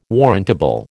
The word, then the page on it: warrantable: American English